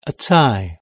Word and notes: a tie: British English

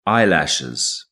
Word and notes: eyelashes: British English

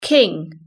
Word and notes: king: British English

